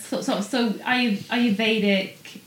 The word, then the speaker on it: Ayurvedic: Am I saying that right? Yeah. Ayurvedic. – You can uh, say Ayurvedic or you can say Ayurvedic. Ayurvedic. But I always say Ayurvedic.